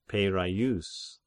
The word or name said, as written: Peiraieus